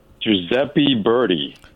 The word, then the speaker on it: Giuseppe Verdi: Giuseppe Verdi.